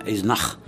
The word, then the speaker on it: Eisenach: Bien, Jean-Sébastien Bach est né à Eisenach, on devrait dire, on va le prononcer en allemand, Eisenach, le 21 mars 1685.